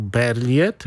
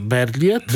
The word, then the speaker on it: Berliet: to znowu Berliet. Tak, francuska wymowa piękna u redaktora Pawła Sity. A ja tam po prostu jako pasażer mówię Berliet.